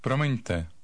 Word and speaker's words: Promiňte: Promiňte. Promiňte. – Do it once more. Make sure you're getting the stress right on the first syllable.